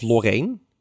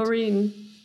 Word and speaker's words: Loreen: Loreen. Of Lorraine. Hoe spreek je het uit? Loreen.